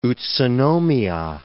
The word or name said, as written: Utsunomiya